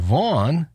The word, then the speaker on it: Vaughan: And Vaughan rhymes with born.